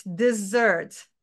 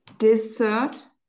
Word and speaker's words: dessert: Dessert. – Dessert. – It's not dessert. It's dessert.